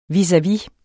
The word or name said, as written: vis-a-vis